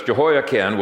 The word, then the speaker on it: Jehoiachin: The second thing that he planted was the ancestor of Jesus, Jehoiachin, or Jehoiakim, it's pronounced, not Chin, it's spelled Chin, I'm not sure why. But it's Jehoiachin, was very strangely,